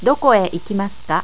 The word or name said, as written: doko e